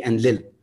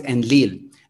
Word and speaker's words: Enlil: And notice that I'm like, the English pronunciation of Enki and Enlil, right? Like anytime you would hear it, it would be Enlil. However, it's in fact, there's an emphasis on the il, which becomes Enlil.